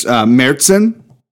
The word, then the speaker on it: Märzen: And so Sarah was drinking a nice Märzen, apparently, is how you actually pronounce that.